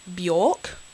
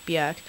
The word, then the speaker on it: Björk: You know, for a very long time, I thought her name was pronounced Bjork, and then I saw her in an interview complaining when people pronounced her name like Bjork, and it's actually pronounced like Björk.